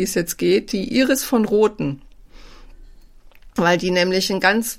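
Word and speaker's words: von Roten: Und über Iris von Rothen und ihren Mann Peter von Rothen, wobei ich weiß, ich sag das immer ein bisschen falsch, in der Schweiz wird es eigentlich von Rothen ausgesprochen, also wird es von betont.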